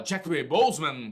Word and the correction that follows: Chadwick Boseman: tem que falar rápido para parecer que falou certo. É, Chadwick que... Boseman. Chadwick Boseman.